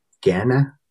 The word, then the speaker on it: Kainai: So um so I'm from the Blood Reserve and we're also called like the uh Kainai but um, in Blackfoot it's pronounced "Gana," right? And so mm-hmm. just a lot of people just say Kainai, just yeah.